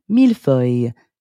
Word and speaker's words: millefeuille: In French, it would be millefeuille. However, in Italian, millefoglie. Millefoglie.